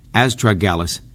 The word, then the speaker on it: astragalus: So again, as I started off, astragalus, that's pronounced astragalus, is known as a guardian root.